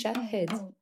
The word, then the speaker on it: Shahd: My name is Shahd Al-Sali, pronounced Shahd.